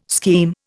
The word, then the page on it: scheme: Indian